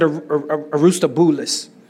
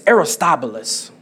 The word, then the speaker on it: Aristobulus: Now, I said this wrong in the first service. I think I said Aristobulus. It's Aristobulus.